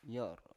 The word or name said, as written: yor